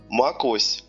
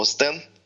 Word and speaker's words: OS X: Они уже, по-моему, несколько версий как не называются Макось, и никогда не назывались X, это, по-моему, OS X.